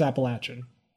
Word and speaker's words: Appalachian: always worry about how to pronounce them. – Well, around here, it's Appalachian.